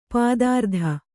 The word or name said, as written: ಪಥಾರ್ತ